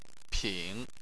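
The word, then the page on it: ping: tone 3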